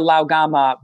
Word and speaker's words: Lao Gan Ma: That Lao Gamma sells millions and millions of jars. And when you, if you were to just Google Chili Crisp or Lao Gamma, which is spelled L-A-O-G-A-N-M-A, what you would find is that the people who eat this stuff are beyond passionate.